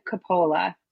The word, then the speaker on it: Coppola: And it's just to let you know, the pronunciation is Corinne Coppola.